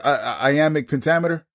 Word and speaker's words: iambic pentameter: Iambic pentameter? – But look at you. Look at you. – Did I even say it correctly? – You said it absolutely. You know you said it correctly, dear brother.